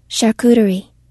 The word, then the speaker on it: charcuterie: Charcuterie. – Charcuterie. You ever heard of a charcuterie? – I feel like I have.